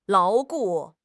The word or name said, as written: 牢固